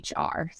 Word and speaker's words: Lahr: It's our last name, and our last name is spelled Lahr.